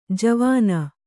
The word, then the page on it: ಜವಾನಿ: kannada